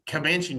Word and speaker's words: Comanche: Comanche Nation Complex.